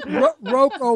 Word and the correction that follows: Roku: will have a canary. – No, Roku. – Roku. Say Roku.